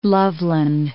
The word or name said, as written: Loveland